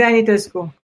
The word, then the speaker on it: Anitescu: Magda Anitescu. – And there's an extra syllable, Anitescu. – Totally phonetic. Romanian is totally okay.